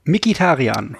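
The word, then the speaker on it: Mkhitaryan: Und äh, heute kam mir der Fall hoch ähm, mit Henrik Mkitarian, wird er so, wie wird er ausgesprochen? Mikitarian. Mikitarian, Dankeschön, äh,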